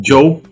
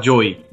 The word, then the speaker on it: Joe: Joe, Joe Pesci. A gente tem maneira de falar Joey. Joey é quando tem um Y.